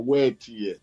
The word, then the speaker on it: Wetie: Official Wete, wet, W-E-T. But pronounce the Yoruba way, Operation Wetie.